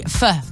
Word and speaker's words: pho: Well, today you don't, because if you're around Botany Town Centre or around Botany at Rolled Out, they have free pho between 5 and 7. Now, that's that soup. It's about like pho, like P-H-O, but apparently it's pronounced pho.